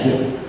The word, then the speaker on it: dục: Về chữ dục, chữ dục đó thì phản ngữ đọc là ra cắt, nhưng là dung. Cái chữ ra, xin lỗi, cái về chữ dục đó thì phản ngữ đọc là ca mắt, nhưng là dục.